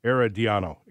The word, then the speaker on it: Herediano: Is Aaron Donio, Aaron Drino? Does anybody know how to pronounce this team's name yet? I got to learn to pronounce it. But anyway, they were the Costa Rican champions. Herediano. That's it.